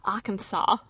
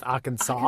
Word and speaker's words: Arkansas: But the first thing we did when we got into Arkansas, I just love saying the name. – By the way, it wasn't until we entered the state that I understood it to be pronounced Arkansas and not Arkansas.